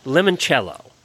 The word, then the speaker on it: limoncello: And Auditor Flossie shares her family's recipe for, I think it's pronounced limoncello. Oh, very good.